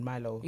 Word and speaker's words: Milo: It's actually pronounced Milo. – It's Milo. Yeah. – But the Ghanaians pronounce it Milo.